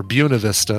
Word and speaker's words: Buena Vista: BV for Buena Vista, or Buena Vista, it's pronounced.